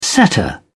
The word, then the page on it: setter: British English